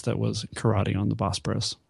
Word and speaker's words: Bosporus: But regardless, new scenes were filmed with Carter Wong, Kong Do, and Bolo Young that was inserted into Karate on the Bosporus from 1974. Is that how you pronounce that area of Turkey? Bosporus. – Yes, I believe that Bosporus is how it's pronounced, but I'm not really an expert, I'm afraid.